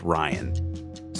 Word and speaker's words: RYaN: So Rian is an acronym, and it actually is spelled like the name Ryan. You're going to hear a variety of pronunciations for this acronym. I'm just going to go with Ryan.